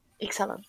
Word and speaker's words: Ixalan: But then I just start to play with another stuff, especially because Ixalan is the way you pronounce in English? – Yes. – Ixalan, yes, thank you.